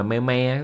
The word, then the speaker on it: meme: Trong tiếng Anh ta nên phát âm meme cho vần với cream. Meme là meme các bạn.